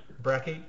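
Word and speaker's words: Brache: And your centres, you know, Godwin and how do you pronounce Marcel's surname? – Bracky.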